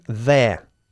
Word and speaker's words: there: Intenta pronunciar las dos palabras. They are, la contracción, there. Not there. There. Yeah? There. – There. – There. – There. – Sacando la lengua.